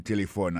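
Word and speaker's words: telephone: Téléphone, c'est comme ça on dit téléphone en anglais. Nous devons répéter encore pour nous, téléphone. N'a pas remarqué que l'accent tonique l'a tombé sous le premier syllabe là.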